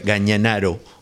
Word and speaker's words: Gañanaru: Así que vamos a escuchar a Aurelio en un tema que se llama Gañanaru, y que él pronuncia Gañanaro, o que se pronuncia así seguramente, pero se escribe Gañanaru.